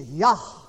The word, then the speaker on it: Jah: Look at the latter part of his name, the J-A-H, pronounced in English, Jah. It is Yah in Hebrew.